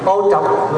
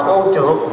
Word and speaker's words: ô trọc: Ô trọc, người Bắc gọi là ô trọc, chúng ta đọc là ô trượt.